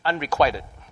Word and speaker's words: unrequited: Unrequited. I used to think it's quitted, but it's quieted.